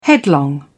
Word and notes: headlong: British English

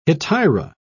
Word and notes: hetaira: British English

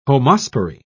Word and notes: homospory: British English

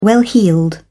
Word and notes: well heeled: British English

